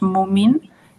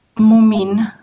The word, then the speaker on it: Mumin: pequeña yo les llamaría los mummies o algo así, pero es mumin y esa era como si fueran unos hipopótamos o algo así blancos.